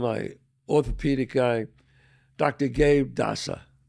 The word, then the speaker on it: Dassa: So a big shout out to Dr. Gabe Dasa. Dasa. D-A-S-S-A. – D-A-S-S-A. – I think I said it right.